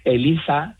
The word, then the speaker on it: ELISA: ELISA, y además está bien pronunciado con Z, aunque yo viva en Cádiz, no es que sea ELISA y yo le llame ELISA, que ya me lo han dicho por ahí, no, es ELISA con Z.